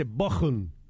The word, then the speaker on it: Bochum: De vuelta Raúl desde Bochum, y me dice, bien pronunciado, con CH, entre dos vocales se pronuncia como J.